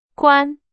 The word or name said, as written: 官